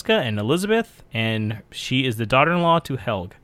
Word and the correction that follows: Helge: Helge, right? Helge is that the best way to pronounce it? Yeah, that's Helge. What Helge. Helge.